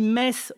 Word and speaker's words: Metz: Oui, effectivement, on dit Metz, on ne dit pas Metz, le T ne se prononce pas.